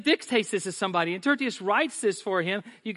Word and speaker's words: Tertius: It's Tertius. I practiced. That's the hardest one for the whole list for me is Tertius.